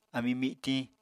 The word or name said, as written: ami mitiñ